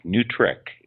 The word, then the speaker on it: NWTRCC: Newtrick is how that gets pronounced, .org.